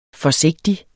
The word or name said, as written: forsigtig